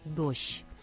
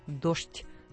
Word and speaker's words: дождь: В одном городе произношение было более мягким – «дождь», в другом – чеканным – «дождь», но зато и «чтобы».